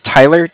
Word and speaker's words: Tyler: Okay, my name is Tyler, T-Y-L-E-R. – Uh-huh. – And I'm calling with Cardiff. It's spelled C-A-R-D-I-F-F, as in Frank Frank.